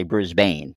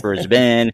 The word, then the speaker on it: Brisbane: You know, like a lot of Americans say Brisbane, but we know it's Brisbane. Melbourne is actually Melbourne,